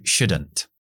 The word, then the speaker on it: shouldn't: Esa T está al final de la palabra y está entre una N y una H. Por lo tanto, fuera. Ni tenemos que pronunciarla. T's entre consonantes no se pronuncian. Entonces, you shouldn't. Muy importante, corto, la L no se pronuncia. Shouldn't, shouldn't. Have, si tienes un nivel un poco más de cero, sabrás que podemos contraer have y se queda en v, en una v.